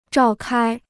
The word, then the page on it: 召开: zhào kāi